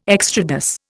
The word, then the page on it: extrados: Indian